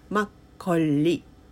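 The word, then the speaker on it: マ ッ コ リ (막걸리): それがマッコリ、カタカナだとマッコリってなっちゃうんですけど、韓国語だとマッコリ、マッコリ、ちょっと L の音っていうか R の音っていうかっていうのがちょっと間に 2 つ入るので発音ちょっと難しいんですけど、マッコリですね。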